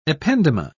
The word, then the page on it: ependyma: British English